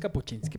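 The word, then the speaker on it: Kapuściński: Pero es Kapuchinsky para los cuates. Entonces. como capuchino. Como capuchino, ándale. Y entonces él,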